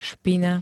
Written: špina

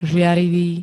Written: žiarivý

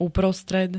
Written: uprostred

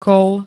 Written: kôl

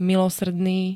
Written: milosrdný

